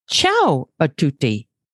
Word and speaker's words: Ciao a tutti: Ciao a tutti.